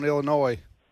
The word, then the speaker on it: Illinois: Anyway, the S is silent on Illinois.